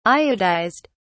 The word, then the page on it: iodized: British English